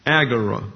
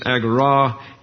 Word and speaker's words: agora: And so the viticulturalist and the unemployed scabs meet in the agora. That's the way it's pronounced in English. It's agora in the Greek. Ente agora is the Greek phrase there.